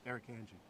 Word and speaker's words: Irukandji: But it's a good icebreaker, and it's a good way to pronounce Irukandji, because if people have trouble reading it or trying to repeat it, we just say, just like the jellyfish, Irukandji.